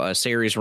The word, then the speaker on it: Saoirse: The Irish girl, Saoirse Ronan.